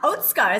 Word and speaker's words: Otsuka: He is the Senior Managing Director for Otsuka, which is uh, Otsuka is how it's pronounced, pharmaceuticals.